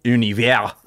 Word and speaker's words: Univers: Univers. – Oh, there we go. Is that how you actually say it, though, to your mates? – No, I would say Universe for sure.